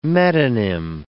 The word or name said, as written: metonym